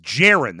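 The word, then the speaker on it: Geron: It's Jaron. That's how you say his name.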